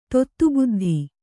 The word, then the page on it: ತೊತ್ತುಬುದ್ಧಿ: kannada